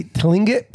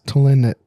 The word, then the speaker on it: Tlingit: T-L-I-N-G-I-T. – T-L-I-N-G-I-T. – Tlingit. – Lingit. – Tlingit. – Anyway. – The T is probably silent. It's probably like Lingit.